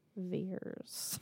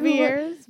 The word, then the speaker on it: Veirs: No, opening theme song performed by Laura Veers. Veers. – However. – V-E-I-R-S. – I remember you pronouncing this name incorrectly last time, not knowing Veers. Veers.